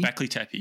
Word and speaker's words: Göbekli Tepe: Göbekli Tepe, yes. – Or Tekke. Göbekli Tekke or Tepe? – Tepe. – Göbekli Tepe.